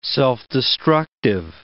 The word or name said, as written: self-destructive